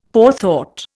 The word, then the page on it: forethought: Indian